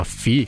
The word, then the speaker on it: Phi: O episódio de hoje é patrocinado pela primeira vez pela plataforma FI, que se escreve PHI, e eu falo mais sobre ela daqui a pouquinho.